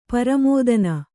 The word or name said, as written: ಪರಮೋದನ